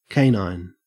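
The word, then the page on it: canine: au Australian English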